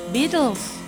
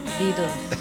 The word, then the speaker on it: Beatles: ¿cómo se dice en inglés? – Beatles. – Beatles, a ver otra vez. – Beatles.